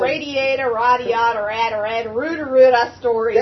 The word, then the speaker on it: Radiata: That's how Freddy said it, and I was like, dude, that actually freaking makes sense. – Radiata stories? – Radiata. – So that's the official pronunciation. Okay, radiata stories. We have it. You heard it here.